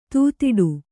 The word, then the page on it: ದೂದಟ್ಟು: dūdaṭṭu